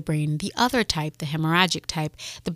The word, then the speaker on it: hemorrhagic: Hemorrhagic, yes.